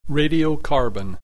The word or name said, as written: radiocarbon